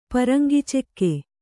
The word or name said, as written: ಪರಂಗಿಚೆಕ್ಕೆ